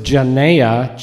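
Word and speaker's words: genea (γενεά): I used to pronounce it genea, G-E-N-E-A, the accents on the last syllable, not the next to the last, and the G is pronounced like, you know, the Greek sandwich that you find at Greek delis, gyros, that's pronounced gyro, because the G is pronounced like cross between a G-H and a Y.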